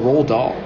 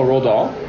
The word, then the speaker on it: Roald: How do you spell Roald Dahl? – Oh, roll, as in like roll, like a roll. – Yeah, like butter on a roll. – That's not how it's spelled. – Yeah. It's R-O-H-L-L-L-D-O-L-L.